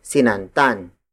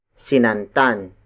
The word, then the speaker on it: sinantan: After kati, we have sinantan. Sinantan. Sinantan. Sinantan is spelled as S-I-N-A-N-T-A-N.